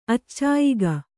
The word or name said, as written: ಅಚ್ಚಾಯಿಗ